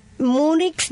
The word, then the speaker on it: Múnich: Y mostraron a los medios de comunicación esta obra, que fue contemplada por última vez en Múnich, allá en Alemania. Se dice así, ¿verdad? Múnich. Yo creí que, bueno, diciéndolo en inglés, sí, me sale Múnich.